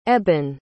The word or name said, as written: eban